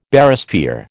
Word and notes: Barysphere: American English

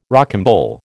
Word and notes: rocambole: American English

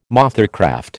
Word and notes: mothercraft: American English

